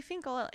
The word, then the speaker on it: Finkel: And I used to, growing up, you know, during like icebreaker games, I'd be like, my name's Barry Finkel. It rhymes with periwinkle.